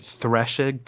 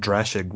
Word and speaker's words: Drashig: Wait, are you pronouncing it thrashig? THR? It's well, because there's a reason. It's actually spelled Drashig with a D at the beginning, mm-hmm. and there's a reason. It's D spelled D R A S I, Drashig D R A S H I G.